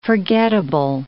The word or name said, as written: forgettable